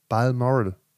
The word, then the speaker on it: Balmoral: B-A-L-M-O-R-A-L. – Balmoral. – Balmoral.